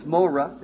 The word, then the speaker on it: mowreh: The word for former rain is morah in the Hebrew. M-O-W-R-E-H is a way to transliterate it, in which you can pronounce it easily. And, because sometimes it can be just M-O-R-E-H.